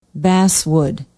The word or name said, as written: basswood